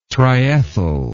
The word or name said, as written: triethyl